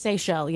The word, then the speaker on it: Seychelles: Is it pronounced Seychelles? – Seychelles, yeah. – The Seychelles, the islands. – S-E-Y-C-H-E-L-L-E.